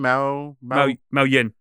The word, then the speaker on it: Mao Yin: Mao Yin. – Yeah, I'm sorry, my pronunciation. That's it, it's like a cat meowing, like when it meow, meows, yeah.